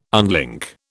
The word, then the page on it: unlink: British English